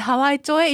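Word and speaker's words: 羽合町: そうです、あの今ね調べました、ハワイ町と読みます。 – 鳥取にハワイがあるってこういうことだったんですね、はい、ハワイ町へ移動して温泉旅館で一泊、浴場は近くの東郷湖で立ち尽くしてから梨狩りで可愛い翔ちゃんを取り、最後は鳥取砂丘で立ち尽くして、これ立ち尽くすの2 回あるんだね。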